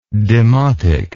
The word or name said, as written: demotist